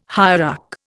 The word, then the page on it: hierarch: Indian